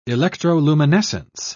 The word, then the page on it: electroluminescence: British English